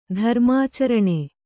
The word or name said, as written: ಧರ್ಮಾಚರಣೆ